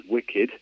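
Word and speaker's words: WCKD: known as Wicked, pronounced like the drink but not spelt like it, I should hasten to add.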